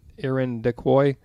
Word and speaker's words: Irondequoit: Irondequoit Cemetery. Yeah, it's spelled like iron to quote, but anyway, that's probably perfect right there.